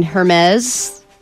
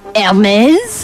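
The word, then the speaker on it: Hermès: I don't think you say it like that. – Isn't it Hermes? – I think the H is silent. – Hermes? – That's how you do it.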